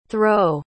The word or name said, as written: throwe